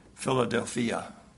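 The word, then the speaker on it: Philadelphia (Φιλαδελφία): And the Greek word is pronounced, it sounds odd to us, Philadelphia.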